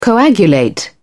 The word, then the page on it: coagulate: British English